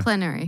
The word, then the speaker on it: plenary: Yeah, it's plenary.